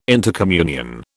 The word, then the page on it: intercommunion: British English